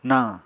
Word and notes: nañ: Apatani

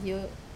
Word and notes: o: Wamesa

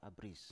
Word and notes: abris: Biak

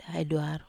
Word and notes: ai duaro: Wamesa

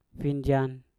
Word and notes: finjan: Mpur

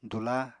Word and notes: dula: apatani

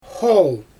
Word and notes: xool: Xyzyl

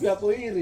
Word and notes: pia koiri: Ansus

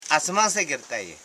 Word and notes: asmansegetai: as.man.se.ge.tai

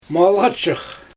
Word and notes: malGapshyx: Xyzyl

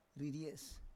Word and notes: riries: Roon

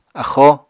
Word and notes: axóo: apatani

